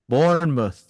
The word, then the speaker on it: Bournemouth: Bournemouth.